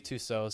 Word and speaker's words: Tussaud: If you look it up on, like, Google Images, it's Louis Tussaud. So, like, Louis spelled like Louis, and then Tussaud is Tussauds. – What? – Yeah, I know, it's not spelled like it's pronounced at all. That's why I had to look it up.